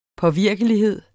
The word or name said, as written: påvirkelighed